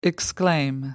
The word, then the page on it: exclaim: American English